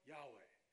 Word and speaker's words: Yahweh: and either pronounce it Yahweh or Yahuwah.